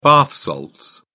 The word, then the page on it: bath salts: British English